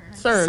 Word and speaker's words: CERN: Is it CERN? Kern? – CERN. – CERN. I'm going to call it CERN.